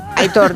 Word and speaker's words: Aitor: Es un oyente, sí, que dice, por favor, que Aitor se pronuncia como se lee. – Sí, ¿no? Aitor. – Aitor. La acentuación es en la O.